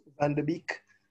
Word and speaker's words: Van de Beek: Van de Beek.